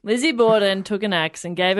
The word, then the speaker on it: Borden: No, it rhymes with Fizzy Forden. – Oh, Lizzie. – Yep. – What's the second bit? Forden. Horden, Morden, Corden, Dorden, Borden. – Borden. – Lizzie Borden.